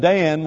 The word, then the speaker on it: Dan: And I'm fortunate, I think, that because my name Dan rhymes with man, when I read verses in the Bible that has the word man in it, I read that as Dan.